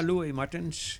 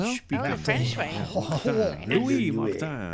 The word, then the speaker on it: Martens: Is it Martens? How you pronounce it? – Yeah, Louis Martens. Martin, you can also say in the French. – Louis Martin.